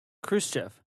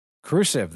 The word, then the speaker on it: Khrushchev: Khrushchev. – Khrushchev, that's right. Nikita Khrushchev. – I didn't quite say it right, but I nailed it.